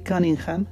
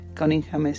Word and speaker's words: Cunningham: Y hace poco descubrí a un artista que se llama Charlie Cunningham. Charlie Cunningham se escribe Charlie y e al final. Cunningham, C-U-N-N-I-N-G-H-A-M, Cunningham.